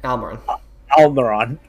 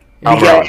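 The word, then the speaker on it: Almirón: I'm probably going to butcher this name, but Almiron? – Almiron. – Almiron, all right. I think I was trying too hard the first time and then got it right the second.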